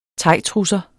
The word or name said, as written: thaitrusser